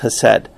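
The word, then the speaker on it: chesed: Now, Hosea, according to Vines, the word that used there is hesed, C-H-E-C-E-D, which is pronounced hesed.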